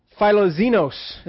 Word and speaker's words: philoxenos: The Greek word here for that is, I don't know if I'm going to say it, philoxenos is how you say that.